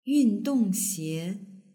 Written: yundongxie

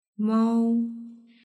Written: mao

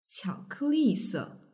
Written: qiaokeli

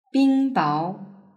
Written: bingbao

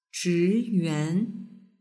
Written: zhiyuan

